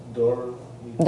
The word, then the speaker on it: dormita: The other thing is you mentioned a few different times the word dor mita. Dor mita. – Dor mita, can you spell that? – D-O-R-M-I-T-A.